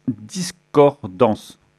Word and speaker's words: Discordance: Voilà, nous sommes avec Stéphane de Discordance. Je le prononce bien ? C'est bien comme ça ? – C'est pas en... c'est bien comme ça, ouais.